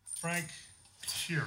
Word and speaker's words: Sheeran: Frank Sheeran.